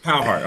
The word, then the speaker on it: Cowherd: Cowherd. I'm sorry. Yeah. I mean to butcher his name.